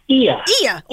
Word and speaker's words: Oia: Oia Village? How's that pronounced? – Ia. – Oh, I had never guessed that. Oia. Ia Village.